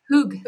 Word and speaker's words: hygge: And realize we actually didn't have any of that calm, quiet, peaceful hygge. Is that how you say it? – Hygge. Yeah.